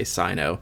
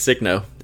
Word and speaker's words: Signo: Just like Signo, I always say Sino. I always forget that in my pronunciation guide. – I think I need Sino. Sino definitely makes more sense than Signo. – Yeah, but I don't know. It's Signo. It is definitely Signo.